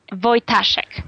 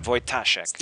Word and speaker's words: Wojtaszek: Well, it's tricky. Polish is a beautiful language, and it's Wojtaszek. – Wojtaszek. – The emphasis is on the A. Wojtaszek.